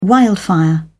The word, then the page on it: wildfire: British English